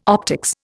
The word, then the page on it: optics: Indian